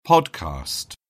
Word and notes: podcast: British English

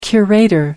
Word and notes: curator: American English

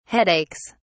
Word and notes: headaches: British English

